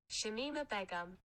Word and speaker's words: Shamima Begum: Shamima Begum.